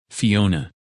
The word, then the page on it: Fiona: English